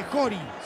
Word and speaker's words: Chori: El 19 es Jori, se escribe Chori, pero se dice Jori.